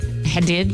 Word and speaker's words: Hadid: Gigi Hadid.